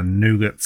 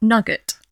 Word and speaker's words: nougat: With a nougat centre. So, you know. – Nougat, not nugget.